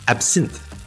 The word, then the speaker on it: absinthe: Spelled A-B-S-I-N-T-H-E, in French, it's actually pronounced absinthe. So you don't pronounce the H-E at the end. It's just absinthe.